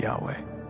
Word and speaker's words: Yahweh: Living within us, pronounced Yod He Vav He Yahweh.